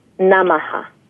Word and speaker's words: Namaha: So the mantra itself sounds like this: Om Shrim Lakshmiye Namaha.